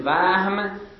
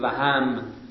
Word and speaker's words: وهم: البته میدانید که کلمه وهم دو جور تلفظ میشود. یک وقت میگیم وهم به سکون الها، یه وقت میگیم وهم به فتح الها.